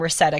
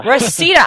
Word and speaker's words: Reseda: Speaking of which, I would like to take a call, also from Reseda, California. Wow, Reseda, pardon me. – Reseda. – I said to Debbie last segment, I had never heard of it, so you've got to forgive me for mispronouncing it.